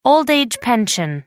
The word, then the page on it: old-age pension: American English